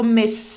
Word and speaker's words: Miss: MS vyslovíme jako MIS, jako opravdu kdyby tam bylo Z na konci, a Miss jako slečna vyslovíme jako Miss se s na konci. Takže Miss, nebo je zvučný z na konci a Miss nezvučné S.